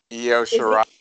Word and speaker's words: Io Shirai: How do you say her? I can't say. Io Shirai. She was in. – Io Shirai. – Is it Io? I've been pronouncing it wrong for so many years.